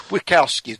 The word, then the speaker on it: Witkowski: And we are here right now with a very special guest, Butch Witkowski.